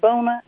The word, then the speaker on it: Boona: Sir, my name is Bona, and I'm calling from Dallas, Texas. – How do you spell that, Bona? – B-O-O-N-A.